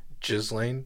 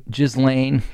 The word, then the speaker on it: Ghislaine: How do you say her name? What's her name? Ghislaine. – Ghislaine.